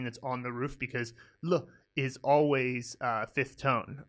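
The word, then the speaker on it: Le (了): Now, this character can actually also sometimes be pronounced Liao, and that's its secondary pronunciation, so we don't teach secondary pronunciations when you first learn a character. You'll learn that later, but that Liao is also a very somewhat common usage of Le, but still, Le is way more common than Liao, so we'll go with L-E, fifth tone, so it's on the roof.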